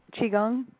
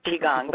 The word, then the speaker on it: Qigong: Qigong. – Qigong, but it's spelled with a Q, isn't it? – Yes.